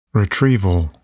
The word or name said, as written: retrieval